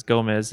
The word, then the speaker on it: Gomez: Whenever it just happens to be things that are named after the stuff that rhymes with my last name. But basically, I think it started with, well, Showmez started because of my name. My last name is Gomez, and me and my brother worked together at a summer camp that he was at for a long time.